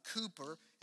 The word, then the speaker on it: Cowper: There was a man in the late 1700s named William Cooper. It's spelled C-O-W-P-E-R, but he was a friend and a contemporary of John Newton.